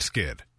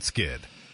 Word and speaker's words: Skydd: Plant Skid. That's spelled plant, S-K-Y-D-D. Plant Skid.